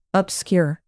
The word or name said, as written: obscure